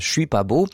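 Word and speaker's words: Chuis pas beau: oui, prononcé comme ça, puisque c'est C-H-U-I-S, « Je suis pas beau ».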